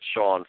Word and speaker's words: Sean: My name is spelled there, but if you want me to spell it, it's Sean, spelled the right way, S-E-A-N. And, right. – My last name is spelled P-E-R-O.